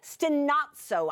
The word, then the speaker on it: stenazo: The, the lexical term for it is stenazo. Stenazo. I'm going to spell it to you just in case you like words like this S T E N A Z O.